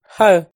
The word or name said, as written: Hawj